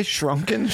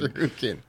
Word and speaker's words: Shuriken: Did you say Shrunken Shannon? – Yeah, what was that? What is that name you said? – Shuriken.